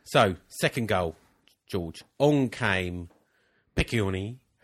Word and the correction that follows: Piccione: Or Piccione. – Piccione, even.